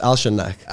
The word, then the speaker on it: Alshannaq: It's Alshannaq. – Alshannaq, right? Yeah. And safe. – Yes. – But it's spelled S-E-A-F, right? – Yeah, safe in Arabic stands for sword.